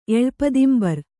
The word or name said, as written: ಎಳ್ಪದಿಂಬರ್